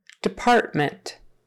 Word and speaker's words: department: So that first syllable is more like a D, D you can say department. Often we'll just say department, department, partment, department, eh or eh on the end, but the syllable stress is in the middle.